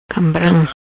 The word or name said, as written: Cambram